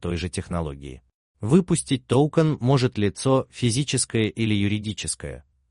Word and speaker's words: токен: Токен ударения в слове. Ударение в слове ставится на О.